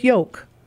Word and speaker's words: yolk: It rhymes with yolk. Y-O-K-E. The same is true for folk. F-O-L-K. You don't pronounce the L.